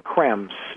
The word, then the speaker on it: Creme: Krems. It's spelled C-R-E-M-E.